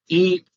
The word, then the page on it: i: Sanskrit